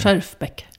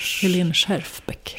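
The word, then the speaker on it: Schjerfbeck: Du, senast vi såg så pratade vi om att du gärna ville köpa en Helene Scherfenbeck. Jag kan inte uttala det. – Scherfbeck. – Ja. – Helene Scherfbeck. – Scherfbeck.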